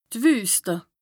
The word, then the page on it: d' Wüste: Bas-Rhin; Strasbourg